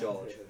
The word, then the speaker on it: Jorge: No, it's George, it's spelled George, like George Lucas, and then it's George spelled Jorge, but pronounced George, because it's Americanized.